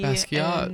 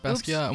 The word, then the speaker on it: Basquiat: Yeah, yeah, yes, which is by the same artist that me Basquiat. – And, yes, that's how you say it. That's not how I said it out loud. – It's Basquiat, and we went to the DC Smithsonian.